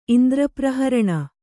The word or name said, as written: ಇಂದ್ರಪ್ರಹರಣ